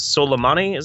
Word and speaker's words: Soleimani: goes and kills Soleimani.